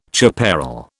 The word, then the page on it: chaparral: British English